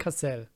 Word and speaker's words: Cassel: Ey, Vincent Cassal, Vin, Vincent. Wie wird's ausgesprochen? Vincent Cassal? – Mhm. – Vincent? Vincent. – Partner. Vincent Cassel. – Cassel. Cassel genau.